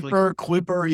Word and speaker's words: Kuiper: I actually don't know how to pronounce it correctly. – Kuiper, yeah, I mispronounced it earlier too.